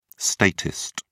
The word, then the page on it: statist: British English